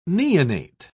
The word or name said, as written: neonate